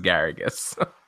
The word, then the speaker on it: Garrigues: This one I know is Garrigues. – Garrigues. I want to put a little more emphasis on the I.